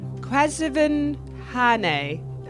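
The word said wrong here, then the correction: Quvenzhané Wallis: It is Quevenzane Wellis.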